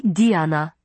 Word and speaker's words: Diana: You are only Diana, she said, pronouncing it in the French style as she always did, with the emphasis on the first syllable.